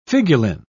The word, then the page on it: figuline: British English